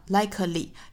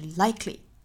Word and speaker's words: likely: 不应该读成哒哒哒 likely，应该是 likely likely likely 两音节。